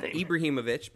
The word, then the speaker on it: Ibrahimovic: Ibrahimovic, but I feel like you got close enough.